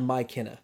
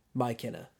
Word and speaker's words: Mykenna: It's spelled Mykenna. – I know. – So, like, M-Y, capital K, Kenna. Mykenna. – Okay. – So, if you're going to spell it like that, that's how I'm going to say it.